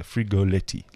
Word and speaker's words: Frigoletti: Frigoletti, like you say it, with two T's.